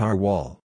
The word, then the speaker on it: RWAL: General Motors and Chrysler version is called Rear Wheel Anti-Lock, abbreviated RWAL, pronounced R-Wall.